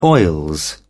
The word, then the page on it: oil: British English